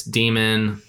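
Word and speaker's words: dæmon: So, like I said, I had a hard time pronouncing it. Apparently, it's Demon.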